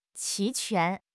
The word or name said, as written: 齐全